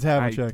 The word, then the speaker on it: Havlicek: Wait, it's pronounced Havlicek, right? – I don't know, dude. – It's Havlicek.